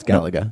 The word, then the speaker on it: Galaga: It was Galaga? Is that how you pronounce it? – Galaga? – Galaga? I don't know. – No, it's Galaga.